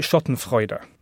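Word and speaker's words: Schottenfreude: First of all, am I pronouncing the name of the book correctly? – Well, I pronounce it Schadenfreude. – Of course you do. – But I've been told I have a terrible German accent, so God knows if I'm right.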